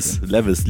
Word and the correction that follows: Levi's: Levi's. – Ach, Levi's, ja, Levi's. – So wird man es ja aussprechen.